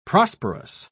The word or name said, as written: prosperous